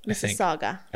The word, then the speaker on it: Mississauga: Mississauga, I think is how you say it. – Oh, there's our ooh. I always put ooh at the end.